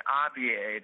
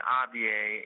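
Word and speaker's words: Abyei: Abaya, that's how you say it, Abaya, is an area in between North and South Sudan that has always been claimed by both.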